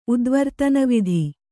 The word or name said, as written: ಉದ್ವರ್ತನವಿಧಿ